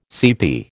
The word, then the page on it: CP: American English